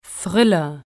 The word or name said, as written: Thriller